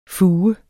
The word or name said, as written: fuge